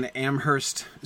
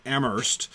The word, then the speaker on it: Amherst: The similar town named after the same person in Massachusetts is pronounced differently. You don't pronounce the H. In New Hampshire, you pronounce the H. In Amherst, Massachusetts, you don't pronounce the H.